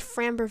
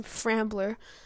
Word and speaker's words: Framber: Starting for the Astros will be Framber Valdez. Now, I used to mispronounce his name, Frambler. I will no longer do that, but he has had two rough starts this postseason.